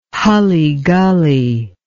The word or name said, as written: hully gully